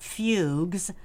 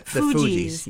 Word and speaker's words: Fugees: She was a member of the, and I'm going to pronounce this like the Bee Gees. It's not Fugues. – Yeah, Fugees.